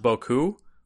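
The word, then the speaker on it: beaucoup: I always thought it was like B-O-O-K-O-O, but you know. – Yeah, it's weird because in French, I think that's actually pronounced Boku. Rather than Buku, but yeah, in English, everyone says Buku.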